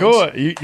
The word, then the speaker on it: Nacua: Nacua, you drilled it.